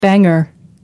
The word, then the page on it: banger: American English